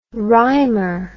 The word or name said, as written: rhymer